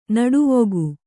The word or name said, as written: ನಟವಿಗ